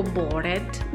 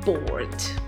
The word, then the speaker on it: bored: Mi raccomando, anche qua la pronuncia. La pronuncia è molto importante perché se io dico bored non mi capirà mai nessuno. La pronuncia esatta è bored.